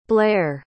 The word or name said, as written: blaire